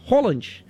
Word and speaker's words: Haaland: E o Haaland surpreendeu todo mundo porque na pronúncia se fala Haaland.